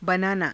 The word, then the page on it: banaanaa: hindi